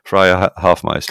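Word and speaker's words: Freya Hoffmeister: Freya Hofmeister,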